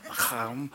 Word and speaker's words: lechem: And lechem, pronounced like the Hebrews do, lechem, is bread.